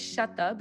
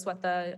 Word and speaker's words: Sweta: Yeah, so it's not spelled the way it's pronounced, which is the bane of my existence, but my name is Swetha, but it's spelled S-W-E-T-A. Oh, it's fine. I pronounce it Bengali style, but Indian pronunciation is Swetha or Swetha.